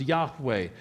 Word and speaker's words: Yahweh: It translates the Hebrew word YHWH, perhaps best pronounced Yahweh, a temple for the name of Yahweh.